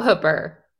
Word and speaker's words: Hooper: Shout out Hooper. – Yeah, is that spelled like Hooper, but pronounced Hooper? – It sure is.